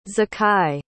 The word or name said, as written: Zakai